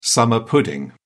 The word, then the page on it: summer pudding: British English